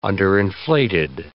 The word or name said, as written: underinflated